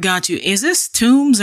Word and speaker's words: Tomes: T-O-M-E-S, I've heard you. – I usually pronounce it Tomes. – Tomes, okay, got you. Yeah, like, and it's a play on words. So tomb, a place where people are buried, and tome is a large book, typically.